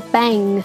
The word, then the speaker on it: bang: 英語はちゃんと G が最後に入ってるので bang って発音しましょう